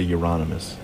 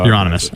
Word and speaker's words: Euronymous: I would say Euronymous. – Euronymous? – Sure. Right. That's how Euronymous. – I pronounce it.